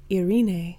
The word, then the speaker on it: Irene: And so her name is pronounced Irene.